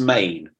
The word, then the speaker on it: Men: And so I managed to get a screener from nice people at the BFI of Ennis Main. It's pronounced Main, right? Ennis Main.